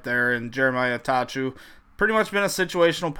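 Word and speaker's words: Attaochu: We had Jeremiah, how do you say that? Atachu? – Atachu.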